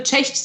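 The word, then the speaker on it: Cześć: Man kann, naja, man könnte Tschech sagen. Tschech sagt man Hallo und Tschüss.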